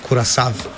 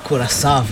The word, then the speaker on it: Curaçao: Druga je bila naslednja, no, Nemčija, Curaçao. – Pravilno se zdaj izgovori Curaçao. – Curaçao, aha.